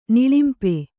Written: ನಿಳಿಂಪ

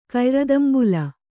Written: ಕಯ್ರದಂಬುಲ

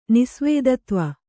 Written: ನಿಸ್ವೇದತ್ವ

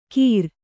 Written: ಕೀರ್

